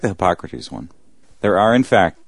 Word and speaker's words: Hippocrates: I think it's pronounced... Hippocrates. – Hippocrates, yes. – I believe it is Hippocrates.